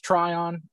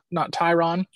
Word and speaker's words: Tryon: I do know that it's pronounced Tryon, not Tyron.